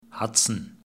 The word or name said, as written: Hudson